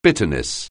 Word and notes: bitterness: anglais

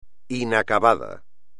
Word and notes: inacabado: espagnol

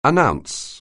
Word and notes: announce: anglais

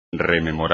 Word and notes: rememorar: espagnol

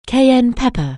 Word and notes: cayenne pepper: anglais

